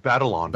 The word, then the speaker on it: Batalon: and Jacob Benelon. I can't remember how he pronounces his name, but I think it's just Batalon. – Batalon, like the way.